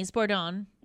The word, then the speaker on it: Sbordone: Okay, so it's S-B-O-R-D-O-N-E. – Okay, S-B-O-R-D-O-N-E, Spordone.